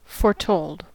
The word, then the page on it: foretold: American English